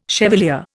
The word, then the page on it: chevalier: Indian